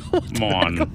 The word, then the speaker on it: Ramon: And I know the last name I'm pronouncing right because in every interview it's like, Ra! – Ramon. – They stress it.